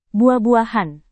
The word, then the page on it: buah-buahan: Indonesian